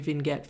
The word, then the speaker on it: vignette: But make sure you get your vignette for your car. Am I saying it right? – I don't know. Maybe I'm saying it wrong. I thought it was vignette. – Vignette. Yeah, you're probably right.